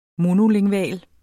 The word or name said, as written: monolingval